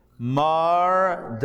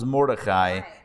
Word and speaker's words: Mordechai: Mardachai. Mardachai. You probably know him as Mordechai. And then you realize we've never been pronouncing his name correctly all this time.